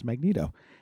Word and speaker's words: Magneto: I always thought it was Magneto. And guess what? It's Magneto. Uh, first appearance was uh, on TV was Spider Man and his amazing friends.